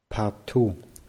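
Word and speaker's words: Partout (в порту): Partout. Partout. Also das O wird dann als A ausgesprochen, weil am Ende betont wird.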